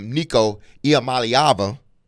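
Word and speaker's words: Nico Iamaleava: So when you're looking at Nico Iamaliava, I hope I pronounced that right.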